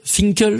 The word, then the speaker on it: Finkel: Irving Finkel, je le dis bien, celui-ci.